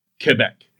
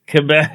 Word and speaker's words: Quebec: She's from Quebec, and her name is K-E-B-E-K, which spells out is Quebec. – Quebec. – It's like, this is how you're supposed to pronounce it, you assholes.